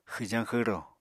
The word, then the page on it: xwjañ-xwro: Apatani